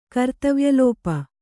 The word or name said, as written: ಕರ್ತವ್ಯಲೋಪ